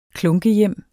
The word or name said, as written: klunkehjem